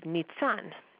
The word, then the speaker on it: Nisan: So our first discusses how Nisan, the month of Nisan, could be pronounced Nitzan.